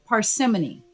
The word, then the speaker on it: parsimony: This week's word I will define and share how to use is the word parsimony, spelled P-A-R-S-I-M-O-N-Y, and pronounced parsimony.